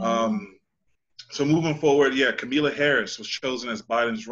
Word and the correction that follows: Kamala: running mate um, kamala harris Kamala. I'm sorry, I've been saying that wrong. I thought it was Camila.